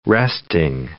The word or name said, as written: resting